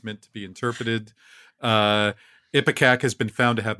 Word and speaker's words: ipecac: Ipecac.